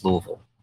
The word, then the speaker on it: Louisville: There's no Louis. There's no S. There's no Louisville. – So Louisville. If you've been there longer, is it just two syllables? Is it Louisville? – Louisville. – Yeah. It's if you're a local, this is kind of like the way to tell if you're from here or not.